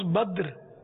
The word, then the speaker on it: بدر: صحیح تلفظ بدر، بدر منیر یعنی روشن چاند.